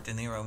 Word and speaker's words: De Niro: Bobby De Niro, am I saying that right? – Yeah, you said right.